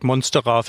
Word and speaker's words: Monstera: Also ich sag Monstera, Fensterblatt. Das ist schon korrekt.